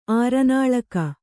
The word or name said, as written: ಆರನಾಲಕ